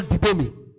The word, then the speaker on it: didomi: Is the Greek word didomi. D-I-D-O-M-I. D-I-D-O-M-I.